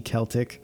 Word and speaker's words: Celtic: Of course, in ancient Celtic times, hard C Celtic, bards were poets.